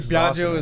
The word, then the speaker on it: Biaggio: But in Italy, they all got it right. And I was like, yeah. – I think Biaggio is like the John of, you know, the U.S.